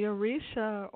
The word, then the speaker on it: Orisha: But first of all, I'd like to ask you, how do you pronounce O-R-I-S-H-A? – Orisha. Yes.